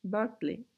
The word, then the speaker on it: Berkeley: Berkeley. Umarım doğru telaffuz ediyorumdur bu arada. Çünkü ama Berkeley'nin doğru olduğunu şey yaptım, Google'ladım. Peki evet bu iki, iki filozoftan bahsedeceğiz.